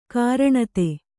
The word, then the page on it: ಘೂರ್ಣಿತ: ghūrṇita